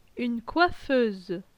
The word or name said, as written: Une coiffeuse